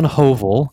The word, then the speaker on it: Hovell: So it's John Hovell. It's how you say the surname.